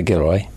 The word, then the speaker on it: Gilroy: Where's the emphasis? The emphasis on the wrong syllable, is it? – Well, the northerners would have the second syllable. – Okay, Gilroy. What would you say? – I would say Gilroy.